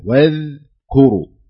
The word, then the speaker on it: واذكروا: لابد انك ترققها تنتبه لها واذكروا يعني الذال ساكنة ومرققة لو تنطقها الآن قلها شف واذكروا أيوة.